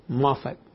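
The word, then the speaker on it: Moffatt: Entonces, si vuelves a poner la versión de Moffat, dice, how do you pronounce it? Moffat o Moffat? Moffat. Ajá, yo también pensé, sí, me decía Moffat.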